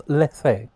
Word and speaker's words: Lethe: Leith. – No, Leith. – I think it's pronounced Lethe. – Lethe, yeah. – Like the Greek Weaver of Forgetfulness.